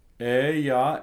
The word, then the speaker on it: eia: Ea ergo, ea ergo. – That I in the middle of the EIA is pronounced as a Y.